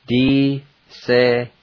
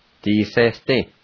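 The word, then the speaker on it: disesdi: D-se-s-ti.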